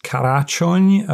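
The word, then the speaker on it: Karácsony: I think his name is pronounced Karaczony, I believe, a Hungarian word for Christmas.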